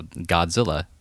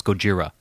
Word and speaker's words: Gojira: They said, no, Godzilla. She's like, no, it's Gojira.